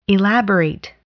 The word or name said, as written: elaborate